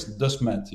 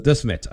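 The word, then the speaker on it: Desmet: Everybody's struggling with the pronunciation, but it's Dismet, yes. But Dismet, okay, right.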